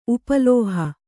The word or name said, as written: ಉಪಲೋಹ